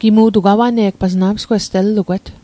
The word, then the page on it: awane'g: Mi'gmaq